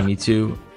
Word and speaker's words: Mewtwo: Mewtwo. Is that how you pronounce it? – Yeah. – Mewtwo.